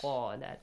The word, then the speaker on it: قَالَ: قَا has a heavy sound. وَإِذْ قَالَ. Not وَإِذْ قَالَ. No. وَإِذْ قَالَ. If you pronounce it with qala, it's kind of weird. It's not Arabic, is it? – No. – No. وَإِذْ قَالَ, this is how Arabs speak, yeah?